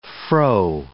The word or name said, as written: froe